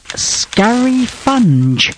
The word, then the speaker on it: scurryfunge: It is scurry funge. S-C-U-R-R-Y-F-U-N-G-E. And it's scurry funge. That's how you pronounce it.